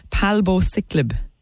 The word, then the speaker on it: palbociclib: Am I pronouncing this right? Palbociclib. – That's right.